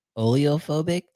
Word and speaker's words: oleophobic: And then now this one is hydrophobic, oleophobic. Did I say that right? – You nailed it.